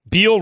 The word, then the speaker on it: Beal: Beal rhymes with deal.